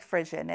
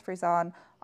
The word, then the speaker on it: Frisson: It looks like Frisson. It's Frisson. – She just pronounced it incorrectly Frisson. Every time. It sounds like a breed of a dog.